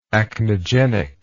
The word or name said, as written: acnegenic